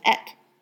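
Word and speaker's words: et (את): But Aleph Tav also is the word, the way you pronounce that is et.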